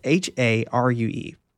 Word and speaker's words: Harue: Uh, Harue, and I feel like I'm saying that right, but Harue, it's H A R U E also shows him.